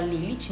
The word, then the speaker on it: Lilith: Maria Madalena no, no momento ela era chamada por outro nome, que era Lilith, né? Acho que é assim que pronuncia.